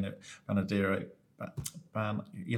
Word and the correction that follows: Bernardino: have to pronounce it from San Bernardino County.